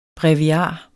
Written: BREVIARS

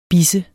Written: bisse

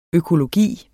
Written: økologi

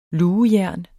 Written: lugejern

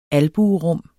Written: albuerum

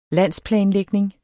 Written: landsplanlægning

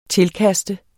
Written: tilkaste

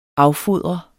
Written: affodre

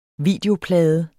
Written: videoplade

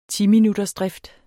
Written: timinuttersdrift

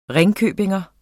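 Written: ringkøbingenser

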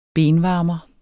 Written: benvarmer

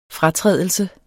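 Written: FRATRÆDELSEN

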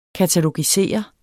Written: katalogisere

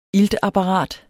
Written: iltapparat

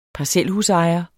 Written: parcelhusejer

